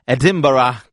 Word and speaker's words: Edinburgh: oggi invece andiamo a Edinburgh, come mi avevano insegnato a pronunciare al linguistico. Ciao Ira! – Ciao, ciao, ti hanno insegnato bene, stati qui, ci tengono molto, ecco. – Sì, perché se ti cimenti col Edinburgh ne esci malissimo, secondo me.